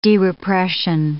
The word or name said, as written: derepression